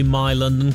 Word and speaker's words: MI London: Now, I want to say My London, because that's what we're being told is the correct pronunciation. But the MI is short for Mumbai Indians. And I don't know if I feel comfortable saying My London when I know it's MI London, because all the other MI franchises, of which there are many, are pronounced MI.